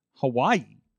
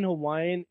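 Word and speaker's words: Hawaii (Hawaiʻi): By the way, did you did you know that in Hawaiian it's not actually pronounced Hawaii? Oh, oh really? Yeah, it's pronounced Hawaii.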